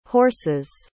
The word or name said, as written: horses